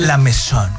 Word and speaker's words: La Mesón: Yo pregunté y se pronuncia La Mesón, porque esa es La Mesón. – La Mesón. – Ajá. – O La Mesón le dicen, ¿no? – Es como cabezón, pero con M.